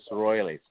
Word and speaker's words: Roiles: But on the line with us right now, Felix, I don't want to ruin your name, butcher your last name, but I was saying Royales, but pronounce your last name for us. – Yeah, it's pronounced as Royales, R-O-I-L-E-S.